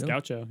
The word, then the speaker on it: Gaucho: I pronounced it correctly. – That's Gaucho. Yeah.